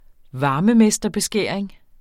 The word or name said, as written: varmemesterbeskæring